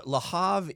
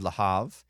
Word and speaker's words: Le Havre: You don't pronounce the R? – It's French, so you can... – It's there. You can hear it. – You go Le Havre. Le Havre. But I don't do that very well.